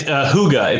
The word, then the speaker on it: hygge: It's hygge. It's a Dutch word. Yeah, it doesn't look like it's it's H-Y-G-G-E.